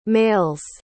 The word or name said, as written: male's